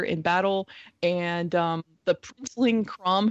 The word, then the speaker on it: Chrom: And anytime I hear Crom, it's spelled Chrom, but I think of Conan the Barbarian's God of War Crom.